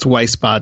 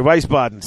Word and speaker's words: Wiesbaden: In fact, now they do have a group, a Facebook group, USAG Weissenbaden Warhammer Community. I don't know if I pronounced that right. – It's just Weissbaden. – Oh, sorry, Weissbaden. So It's WIESBADEN Warhammer Community on Facebook.